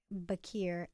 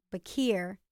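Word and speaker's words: Beccare: Bacure. I pronounced it wrong, too, because I'm from Baltimore, but it's B-E-C-C-A-R-E. – Okay. – Bacure by T.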